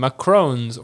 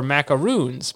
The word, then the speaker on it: macaron: Now, this cookie is easily confused with another due to its name, macarons or macaroons. Macaroons are good. I think they have chocolate in them. Refer to different desserts. The macaron cookie is often mistakenly pronounced as macaroon after the coconut-based macaroon cookie.